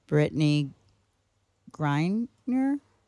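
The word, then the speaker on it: Brittney Griner: Brittany Griner? – Yes.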